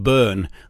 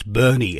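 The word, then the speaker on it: Boerne: which take place in a town which I've always called Bern, and I've been reprimanded. It's Bernie in Texas. It looks like Bern, I know, but it's pronounced Bernie, just north of San Antonio, about an hour and a half's drive from Austin.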